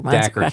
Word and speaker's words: Dacher: I don't know how you pronounce his name, but da- it rhymes with Cracker. Dacher. – Dacher Keltner.